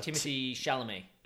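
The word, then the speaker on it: Timothée Chalamet: Is Timothy Chalamet.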